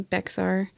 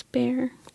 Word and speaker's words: Bexar: But I think the entire time I was listening to her tell her story, it was making me angry because she kept saying Bexar. And I was like, no, it's Bear.